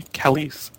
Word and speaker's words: Calise: And color by John Calise. Is that how you pronounce it? I never get this right. – That sounds right.